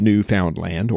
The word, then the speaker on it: Newfoundland: The shores of Newfoundland, or Newfoundland as we pronounce it, were teeming with cod.